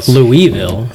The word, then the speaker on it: Louisville: So there's two ways. I would say Louisville as well, but if you talk to a lot of people from there, it's Louisville.